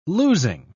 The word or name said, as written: losing